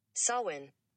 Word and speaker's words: Samhain: So I'll let you hear how you're supposed to say it. – Samhain. – One more time. – Samhain. – One more time. – Samhain. – Samhain. All right? The word Samhain. Now, here's what I'm going to do. Okay, the word is Samhain. Again, it's spelled S-A-M-H-A-I-N, but it's Samhain.